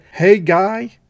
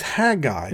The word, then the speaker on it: Haggai: If you're from Mississippi, you may say it Haggai. Two syllables, but it's Haggai, and it's one of the final minor prophets.